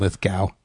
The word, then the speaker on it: Lithgow: I'm thinking of John Lithgow.